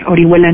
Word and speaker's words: Orihuela: Anamar Orihuela Rico o arroba Anamar Orihuela en Twitter. Y bueno, pues muchas gracias por la invitación, César. – Anamar Orihuela se escribe Ori H Huela, así. – o R I H U E L A.